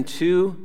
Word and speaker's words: Tew: My name is Ken Tew. It's spelled T E W and pronounced like the number two.